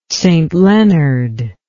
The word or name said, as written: St Leonard